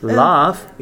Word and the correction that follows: laugh: It's laugh. – Laugh. – Yeah. Laugh. Laugh, be careful, yeah? Laugh, when we laugh, we do ha ha ha ha. That's laugh, not laugh.